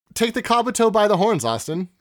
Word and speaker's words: Kabuto: That's how you say its name, Kabuto.